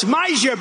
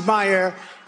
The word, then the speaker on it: Meijer: It's Meijer, but it's actually pronounced Meyer. I said, how the hell do you pronounce this guy's name? Nobody knows him. He's done nothing in Washington. I said, how do you pronounce his name? Is it Meijer? Meijer? They said, it's Meyer.